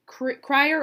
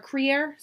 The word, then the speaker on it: Kruger: It's spelled Kruger, but the people in the video were saying Krier or Krier, so gotcha.